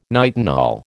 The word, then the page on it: nitinol: American English